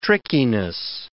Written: trickiness

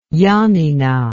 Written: yannina

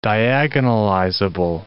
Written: diagonalizable

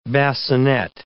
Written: basinet

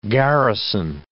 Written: garrison

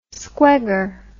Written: squegger

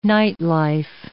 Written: nightlife

